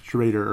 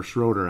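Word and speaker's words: Schröder: Even without Schrader or Schroeder, actually, is his name. I'm used to Schrader when it's spelled that way, but it looks like Schroeder.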